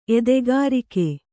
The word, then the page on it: ಎದೆಕರಗು: edekaragu